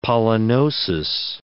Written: pollinosis

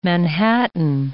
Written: Manhattan

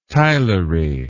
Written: tilery

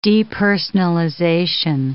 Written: depersonalization

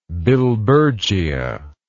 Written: billbergia

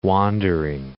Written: wandering

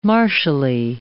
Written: martially

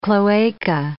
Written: cloaca

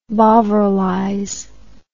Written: bovrilize

